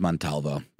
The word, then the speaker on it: Montalvo: I said Montevallo, and it's Montalvo, but I switched up the V and the A.